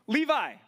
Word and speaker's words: Levi: Old Testament line of, rhymes with Mevi, starts with an L. You wear jeans that are by them sometimes. I don't know, maybe you don't. Levi, right?